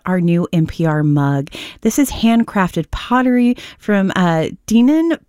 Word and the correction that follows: Deneen: Pottery. Am I saying that right, Zach? – It's Deneen, I believe.